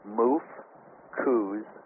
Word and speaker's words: mfkzt: And they called it, well, they called this material, the way it's spelled is M-F-K-Z-T, and how that's pronounced, because the Egyptians did not write with vowels, it's pronounced Muf-Kuz-T.